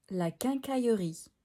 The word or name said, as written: La quincaillerie